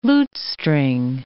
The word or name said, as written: lutestring